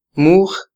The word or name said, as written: muj